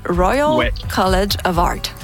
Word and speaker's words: Royal College of Art: C'est le Royal College of Art.